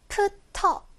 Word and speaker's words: after: after, after 이렇게 피읍, 티긋이 확실하게 띄면 안 되고요. after 딱 이음절이에요.